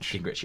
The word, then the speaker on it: Gingrich: I think it's Gingrich? – Gingrich, yeah, Newt Gingrich. – Trying to get the pronunciation right.